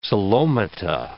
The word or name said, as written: coelomata